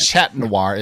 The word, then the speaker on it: Chat Noir: Chat Noir is Chat Noir. it's basically means the black cat in French.